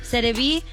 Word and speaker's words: Serie B: Serie B.